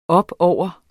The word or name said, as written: opover